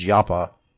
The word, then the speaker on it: Joppa: Now Kiriath-Jearim, that's J-E-A-R-I-M, I'm pronouncing it like the Hebrews pronounce Yapa. Joppa is Yapa.